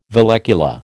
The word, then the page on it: vallecula: American English